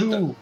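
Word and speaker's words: Tull: É Jethro... como ferramenta. Tu. – Ah, legal. Mas não escreve Tu de ferramenta, né? É com U, né?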